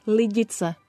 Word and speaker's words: Lidice: Lidice.